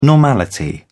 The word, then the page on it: normality: British English